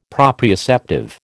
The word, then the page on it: proprioceptive: American English